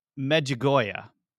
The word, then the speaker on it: Medjugorje: It's called the miracle of the sun in Medjugorje.